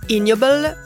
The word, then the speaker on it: Ig Nobel: Ce prix, c'est le IG Nobel, qu'on peut aussi prononcer « ignoble » comme « ignoble ».